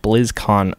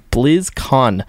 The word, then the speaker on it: BlizzCon: So it does look like Australia is moving on to the World Cup finals, which will take place at BlizzCon. BlizzCon.